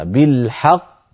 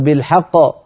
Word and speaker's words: بالحق: كذلك لما تقف على بالحق أنت تنطق قافين، قاف مدغمة وقاف مقلقلة، بالحق هذه القاف الأولى، ق هذه القاف الثانية، فلا تقل بالحق بالحق. أين القاف الأولى؟ يعني بهذه الطريقة القاف الأولى كأنها ما وضحت ولا ظهرت.